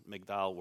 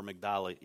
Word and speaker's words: Migdal: that her ancestors came from Migdal, or Migdal, it's either pronounced either way is correct, but my wife's ancestors on her mother's side is from that same area, that same town.